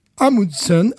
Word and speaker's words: Amundsen: Amundsen, Amundsen, ça s'écrit A-M-U-N-D-S-E-N, e mhm.